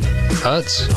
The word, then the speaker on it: Utz: Utz. All around the world. – And I'm guessing that's how you pronounce it. – It is.